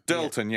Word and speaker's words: Dirleton: And he did another one called, I think it's pronounced Dirleton. – Dirleton, yeah.